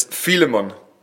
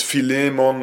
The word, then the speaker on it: Philemon: I don't know really how to pronounce it, so I say it in the German way. It's Philemon. I know in English it's Philemon or something like that, but for me that's so strange.